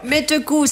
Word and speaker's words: Mette Koe: Et hop, Maître Cool, ça s'écrit M-E-T-T-E K-O-E. – Oui, K-O-E.